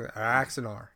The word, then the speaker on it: Axanar: Axer, or how do you say it? – Anaxar. – Anaxar, sorry. – Yeah. I think... Axanar. Axanar. Yeah, that's Axanar.